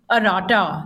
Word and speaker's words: Arata: It's pronounced as arata.